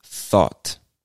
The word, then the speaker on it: thought: And you can think of it not as two words, but what might help you pronounce this word is if you start by saying thaw. Thaw. And then just add a soft T sound to the end.